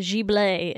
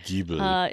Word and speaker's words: Ghibli: Today obviously we watched our first Ghibli movie in a year.